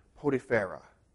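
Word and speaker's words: Potiphera: Potipharah is how it's, pronounced in the Hebrew. Potipharah is how it's pronounced in Egyptian.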